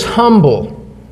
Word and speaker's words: humble: Some people don't like to pronounce the H and say it's a fear that is humble, but we don't talk that way around here, do we?